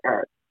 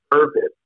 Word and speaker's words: Ervin: Yeah, so the R is silent is a name that's really important to me because my name is actually spelled Ervin, which is Ervin, but okay. The R is silent.